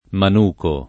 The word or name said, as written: manicare